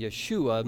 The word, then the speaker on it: Yeshua: Jesus is Joshua in Hebrew, and in the pronunciation would be Yeshua.